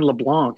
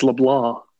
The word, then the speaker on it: LeBlanc: Wade LeBlanc. – It's not even LeBlanc. It's LeBlanc. – No. It's Wade LeBlanc. – All right, we'll go with that one. – We have figured out Wade LeBlanc.